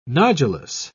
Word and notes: nodulous: British English

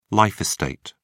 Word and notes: life estate: British English

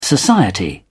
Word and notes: society: British English

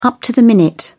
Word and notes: up to the minute: British English

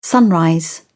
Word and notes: sunrise: British English

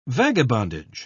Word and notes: vagabondage: British English

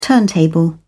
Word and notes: turntable: British English

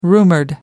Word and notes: rumoured: American English

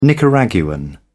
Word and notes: Nicaraguan: British English

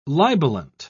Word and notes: libelant: British English

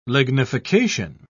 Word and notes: lignification: British English

